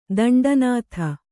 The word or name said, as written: ದಂಡನಾಥ